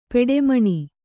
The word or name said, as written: ಪೆಟ್ಟೆಮಣೆ